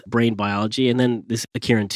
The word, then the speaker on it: Akirin: And we also discussed a little bit about a gene called, I'm not sure I'm pronouncing it right, but is it called Akirin? – Yeah, Akirin 2.